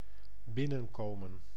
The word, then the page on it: entrer: France; [ɑ̃.tʁe]